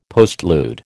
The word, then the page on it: postlude: American English